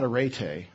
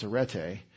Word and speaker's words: Areté: And I've had questions, why is it called Camp Areté, A-R-E-T-E? That's not Areté, it's Areté, because the epsilon says eh, as we pronounce Erasmus in Greek, if you're interested.